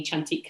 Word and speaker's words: Cantik: And someone at my villa was always calling me Chantique. And I was like, ooh, interesting. It's actually spelled C-A-N-T-I-K, but the Indonesians pronounce it, well, the Indonesian correct way is chantique.